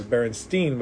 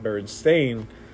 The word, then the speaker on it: Berenstain: Like people would say, "Oh, spell the Berenstein Bears," and you would spell it like it says Berenstein, but it's actually pronounced Berenstain. And you look at the spelling, it would say Berenstain.